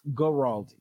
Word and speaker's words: Guaraldi: Is it Guaraldi? – I think it's a hard G. – Okay, Vince Guaraldi.